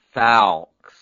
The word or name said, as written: falx